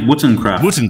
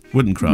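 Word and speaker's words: Wotancraft: I think it's pronounced Wotencraft. – Wotencraft.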